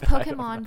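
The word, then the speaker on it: Pokémon: Well, it's Pokemon because in Japanese, K-E is ke. – Mm. – And so, like, Americans say Pokemon, and ki is K-I in Japanese. So that's why it's Pokemon.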